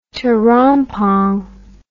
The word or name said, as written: terompong